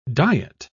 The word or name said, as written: diet